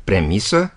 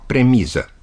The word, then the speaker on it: premisă: E bine, exact așa stau lucrurile și cu substantivul premisă, deși multă lume spune și scrie premiză.